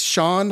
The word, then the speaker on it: Shon: his name is Sean Hopwood, but it's spelled S-H-O-N.